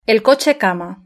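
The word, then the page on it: el coche cama: Spain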